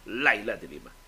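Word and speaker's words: Leila de Lima: Lima gikan ni mismo ni Laila de Lima na ang iyang spelling Laila de Lima pero ang actual pronunciation Laila de Lima.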